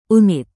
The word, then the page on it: unit: Indonesian